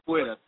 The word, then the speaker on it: capoeira: Now, talk about, and I don't want to pronounce it wrong, it's a capoeira is what you specialize in? – Yeah, capoeira.